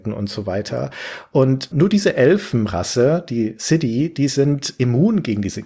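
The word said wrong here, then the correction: Sídhe: Xi spricht man das aus. – Xi spricht man das aus? – Ja, das ist ja ein Originalname aus der keltischen Mythologie.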